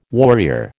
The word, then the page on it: warrior: American English